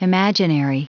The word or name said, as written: imaginary